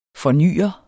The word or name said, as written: fornyer